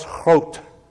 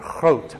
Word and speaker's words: Groot: So I had a man working for me, a Dutch man, Charles Groot. Sounds like you're clearing your throat, doesn't it? Groot, but that's the way you pronounce it.